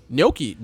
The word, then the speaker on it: gnocchi: Gnocchi. Isn't gnocchi? Is that how you say it? – Yeah.